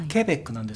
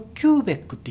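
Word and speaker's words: Quebec: あと Q はケベックなんですけど、これをキューベックって言ってる人がいますね。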